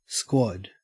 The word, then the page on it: squad: Australian English